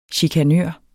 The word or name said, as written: chikanør